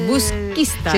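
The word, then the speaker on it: Busquístar: Ahora vamos a retomar la comunicación para hablar de este festival de jazz de Busquistar. ¿Lo he dicho bien ahora? – Busquistar. – Busquistar. El acento. Me ha faltado el acento. Busquistar.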